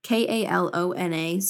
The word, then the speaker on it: Kalona: If you're finding that you can't find something that's local, let's see, what is it called? Coloma? Oh my goodness. I had to look it up. It's Kelowna. I don't know why I can't pronounce this, but they're actually a great brand. It's Kalona Supernatural, and they stand for really great farming practices.